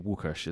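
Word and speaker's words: Łukasz: Like if someone's called Raf or Raphael, it's the same way if someone's called Lucas, it's actually Wukush, is how you pronounce it with kind of their like alphabet.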